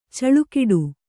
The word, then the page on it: ಛಲಗೇಡಿ: chalagēḍi